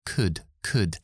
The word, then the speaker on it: could: Could could. Esa palabra no pronunciamos la L. Could, could.